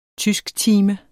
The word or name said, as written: tysktime